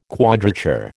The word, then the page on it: quadrature: American English